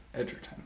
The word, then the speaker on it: Taron Egerton: Taron Egerton. – Okay. – Am I saying that right? – Yes. – Egerton.